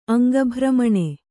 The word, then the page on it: ಅಂಗಭ್ರಮಣೆ: aŋgabhramaṇe; Kannada